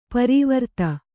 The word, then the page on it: ಪರಿವರ್ತ: kannada